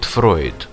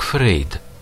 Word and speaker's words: Фрейд: В названии парадоксальным образом сшиты в одно целое, как штанины от разных джинсов, два мировых бренда – Pink Floyd и Sigmund Freud. Наши соотечественники, правда, не смогут оценить все очарование этого фонетического Франкенштейна, потому что фамилия основателя психоанализа у нас произносится как Фрейд.